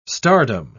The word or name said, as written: stardom